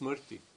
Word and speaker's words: smriti: But this is how it's pronounced, smriti.